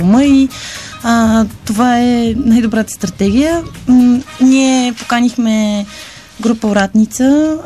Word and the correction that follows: Оратница: или Оратница, както Демай предпочита да се наричат, аз бъркам понякога ударението, и един от най-известните български каба гайда, гайдари, Петър Янев.